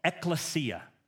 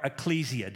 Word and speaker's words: ekklesia: It's called ekklesia or ecclesia, depending on how you would pronounce that.